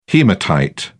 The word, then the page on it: hematite: British English